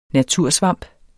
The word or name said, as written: natursvamp